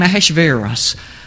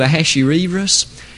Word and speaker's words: Ahasuerus: Some pronounce his name Ahasuerus. I'll attempt to stick to Ahasuerus.